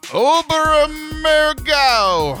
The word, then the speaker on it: Oberammergau: After visiting the Holy Land, you'll head to Germany to see the famed, and everybody loves when I say this word, Oberammergau. Oberammergau.